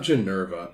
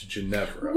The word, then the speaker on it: Ginevra: It's not Ginevra. It's Ginevra. – Well, okay. So I think part of it is because it's very close to Guinevere. – Yeah. – That people want to kind of pronounce it that way.